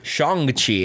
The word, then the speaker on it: Shang-Chi: Shang-Chi, actually. That's how it's supposed to be pronounced.